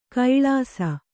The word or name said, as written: ಕಳಸ